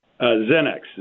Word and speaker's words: Xenex: Joining me by phone, the CEO of, is it pronounced Xenex? – Xenex. It's not pronounced excellent. How it's spelled.